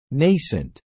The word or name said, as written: nascent